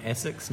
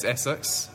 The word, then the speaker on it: Asics: And are we officially calling it Essex, not Asics? What's the in-house? – Well, in-house, I think it's Essex in New Zealand, but I definitely think the correct pronunciation is probably Asics, but we'll just keep the Kiwis.